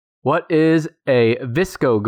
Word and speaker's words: VSCO: The term VSCO, pronounced VSCO, girl, refers to a popular subculture among members of Generation Z, consisting of teenagers following certain fashion choices that go along with trends.